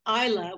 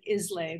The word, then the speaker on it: Islay: But then I found just as a really extreme example, there is a whiskey distillery on the island of Isla, which is spelled Islay. It looks like Islay, but it's pronounced Isla.